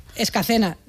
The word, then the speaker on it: Escacena: Nacida y crecida y vivida en Escalena del Campo, en Huelva. Escacena. Mira que te lo he dicho mal varias veces, por ahí está, Escacena.